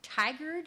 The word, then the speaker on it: Tigard: I got to tell you, my sponsor, Virginia, is speaking at the Tigard. Is that how you say it? Yeah.